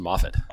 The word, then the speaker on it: Moffat: So it's actually pronounced Moffat. – Oh, yeah. I'm sorry. – No, that's all right. No, Moffat. I mean, it's a tough one to get. – Okay. – Yeah, it's Scottish.